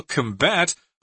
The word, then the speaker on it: combat: Here, combat as a verb means you have to stress the second syllable, combat, although sometimes people may pronounce combat as both the noun and the verb form, but technically you're supposed to say combat.